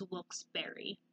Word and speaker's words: Wilkes-Barre: But according to the article, the correct way to pronounce it is Wilkes-Barre.